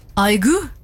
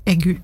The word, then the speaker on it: aigu: Aigu? Hvis jeg så, er det sånn du uttales? – Nei. – Nei. – Egu.